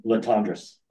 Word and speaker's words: Latondras: Latondras. – Latondra. Doesn't look like Latondra, but it is.